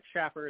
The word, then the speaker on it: Shaffer: I've got Mick Shaffer, M-I-C-K. Last name is spelled S-H-A-F-F-E-R. Looks like Shaffer. It's pronounced Shaffer because my parents were obstinate.